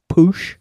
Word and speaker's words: Puig: I think the Catalan surname pronunciation is Push.